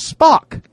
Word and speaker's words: Spock: It says Spock, S-P-O-C-K. Spock rhymes with, you know.